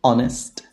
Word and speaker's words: honest: There are only four words in which the H is silent. And obviously the words that are either compound or that come from these words. And they are our, honor, honest, air.